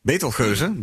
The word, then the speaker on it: Betelgeuze: Hoe spreek je die in Nederlands uit? – Uh, Betelgeuze, denk ik. Betelgeuze? Ja. Oké, cool. Betelgeuze, dus.